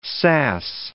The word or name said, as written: sass